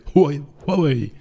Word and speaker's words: Huawei: Donc c'est Huawei qu'il faut, qu'il, faut On n'a pas l'habitude nous hein, de dire ça. Oh, ah oui, ça c'est, la bonne, ce serait la bonne prononciation.